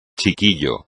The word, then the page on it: chiquillo: espagnol